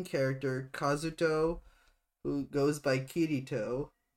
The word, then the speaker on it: Kirito: So so K I R I T O. So like Kirito.